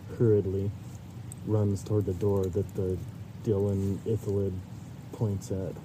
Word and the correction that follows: illithid: Illithid. Yeah. I said it wrong the first time.